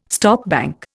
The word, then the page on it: stopbank: Indian